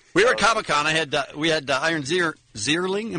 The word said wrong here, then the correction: Ziering: Am I saying that right? Sorry. – Zierling. – Come on now. – Zierling? But at some point, you should know who Ian Ziering is after having just interviewed him again.